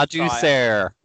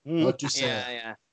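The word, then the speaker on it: Ladouceur: Laduceur. – Laduceur. – It's got, like you say, Canadian, so it's got that French zing to it.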